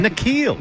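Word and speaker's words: N'Keal: So, Keneal Harry. Is that it? – Nikhil. – Why do I keep doing that? – Nikhil. – They did it. – They did, yeah. Nikhil.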